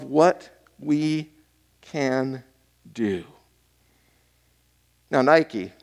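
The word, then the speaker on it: nike (νίκη): It's the word nike. poorly pronounced, Nike. Nike, yeah, the shoe company.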